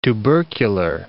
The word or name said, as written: tubercular